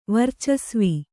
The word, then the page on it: ವರ್ಚಸ್ವಿ: varcasvi